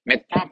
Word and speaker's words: Metta: Now, Metta Paramita is often understood with another term, M-E-T-T-A, Metta, T-T-A, long A, dash, H A V I H A long A R A Vihar.